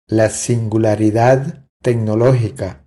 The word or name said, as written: La singularidad tecnologica